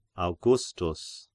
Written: Augustus

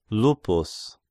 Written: Lupus

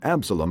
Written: Absalom

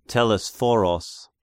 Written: Telesphoros

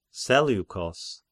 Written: Seleukos

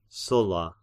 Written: Sulla

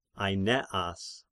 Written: Aineas

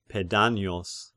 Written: Pedanios

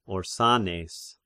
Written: Orsanes

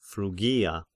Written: Phrygia

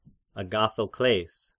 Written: Agathokles

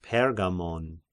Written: Pergamon

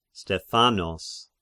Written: Stephanos

